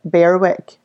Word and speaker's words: Berwick: Like, Brodie's school is on a street called Berwick Drive, but it's B-E-R-W-I-C-K. So everybody here pronounces it Berwick, and I'm like, it's a silent W, it's Berwick.